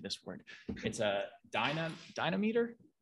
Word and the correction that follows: dynamometer: Is that how you say it? – Dynamometer.